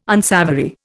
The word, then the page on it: unsavoury: Indian